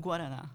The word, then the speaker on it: Guaraná: And I also have been on the quest to bring the green drink, but I can never pronounce the Guaraná.